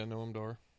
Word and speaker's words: Noam Dar: Noam Dar? Is it? – Yeah, Noam Dar.